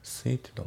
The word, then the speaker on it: suited: で、あと発音ですけど、 suited の、 D がね抜けますね。 suited to、 suited to だね。